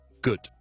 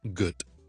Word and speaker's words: good: show 翻埋佢，跟住照读，好似乌鸦个跟住短嘅就系咧，我读 good, G O O D, good, good, good 呢个字嘅读法。 OK，千祈唔好好似有啲人读成咁样 good 咁样咧，就唔好啦，咁就变好奇怪。如果你 good 咧，你嗰条脷个 body 咧系太接近上颚咧，就顶落去。